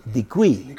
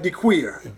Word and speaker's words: Dequey: You know, my great-grandfather, Jerome Dequey, they used to spell it D-E, capital D-E, capital Q-U-E, Dequey. – Dequey, not Dequeer.